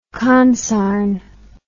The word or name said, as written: consarn